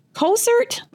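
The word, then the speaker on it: Cowsert: The Senate Special Committee on Investigations, chaired by Republican State Senator Bill Kosert, is how that's pronounced,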